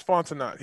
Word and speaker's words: Fontenot: Terry Fontenot? Am I saying it right? – Yeah, Terrence Fontenot.